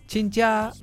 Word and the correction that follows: 亲家: it's pronounced 亲家. This 亲 in Chinese has two pronunciations.